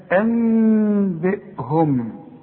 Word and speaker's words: أنبئهم: دي لها الحكم الحكم الرابع في النون الساكنه والتنوين انت ما خدتهوش لكن مؤقتا اعرف انك شوف النطق انبئهم ما تضمش شفايفك وتقول انبئهم ولا تقول انبئهم. مؤقتا يحصل انفراج في الشفايف لغايه لما هناخد الحكم ده ونركز عليه فيما بعد.